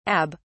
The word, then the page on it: abb: British English